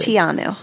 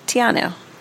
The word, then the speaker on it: Te Anau: But what I did want to ask you is it Teanu? Teanu? – I heard it pronounced T. T-E. – So Tianu. – Yeah. – Tianu. All right, there we go.